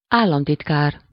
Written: secrétaire

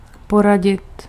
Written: conseiller

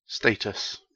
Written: status

